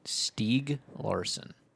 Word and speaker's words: Stieg Larsson: It's funny, because I was going to make reference to Stieg Larsson. Is that how you say his name? – Stieg Larsson.